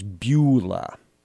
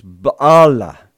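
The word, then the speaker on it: Baalah (בעולה): The Hebrew word here is typically written out and pronounced as Beulah. But the Hebrew actually says Baalah, Baalah, which, weirdly enough, is a Canaanite word.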